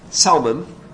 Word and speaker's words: Salmon: Nashon's son, Salmon, it's spelled like the fish, but it's pronounced differently. Salmon did enter the promised land.